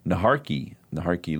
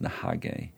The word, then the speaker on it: Naharki: Naharki Loop. I've learned recently that it is it's a Muskogee name, Muskogee Creek name, and it's actually pronounced Nahage.